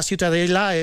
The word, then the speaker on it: Ciutadella: Pero bueno, para mí la Ciutadella es. ¿Cómo se pronuncia? – La Ciutadella. – Ciutadella. Bueno, pues Ciutadella también.